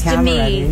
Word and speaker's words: Demi: You know, some people are like, Demi and Demi, how is it actually pronounced? – Remember, it's Demi.